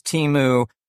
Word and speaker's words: Temu: So I always pronounce Temu as in team.